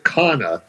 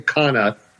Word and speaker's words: Canha: And last night, the Mets, Mark Canha, or as he said, pronounce it Canha. Last night he said, pronounce my name Canha, and I want to be like, I want to be like a Brazilian soccer star.